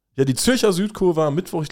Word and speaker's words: Zürcher: Die Zürcher, du hast es fast schon zu gut ausgesprochen. Die Zürcher. Ich, ich, möchte weiterhin appellieren, dass wir das Deutsch aussprechen. Die Zürcher Südkurve.